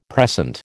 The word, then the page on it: precent: American English